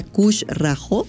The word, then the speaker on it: Khushraho: Entonces, síganlos en Instagram, cerveza Kush Rajo. Cush eh, Kush es K-H-U-S-H-R-A-H-O cush así se escribe, cerveza Kush rajo Ahí búsquenlos, síganlos y vamos a, ir a darles ahí unos, unos regalillos.